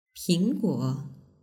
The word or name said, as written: pingguo